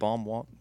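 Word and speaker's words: Baum Walker: I mean, it's spelled B-A-U-M, right? – Mm-hmm. – Bomb Walker.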